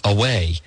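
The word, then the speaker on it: Huawei: But on Tuesday, Gina Raimondo, Governor of Rhode Island and Mr. Biden's Commerce Secretary nominee, refused to pledge that it's H-U-A-W-E-I, but it's pronounced away.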